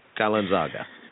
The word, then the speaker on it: Kalenzaga: And Pierre Kalenzaga. – Exactly. It actually is pronounced exactly how it looks, but it's such a long name that it looks more confusing than it actually is.